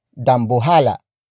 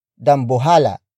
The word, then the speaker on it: dambuhala: The native Tagalog word for whale is dambuhala. Dambuhala. Dambuhala. Dambuhala is spelled as D-A-M-B-U-H-A-L-A.